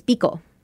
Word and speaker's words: Bico: So short, it's Bico. – Oh. – Bico, B-I-C-O.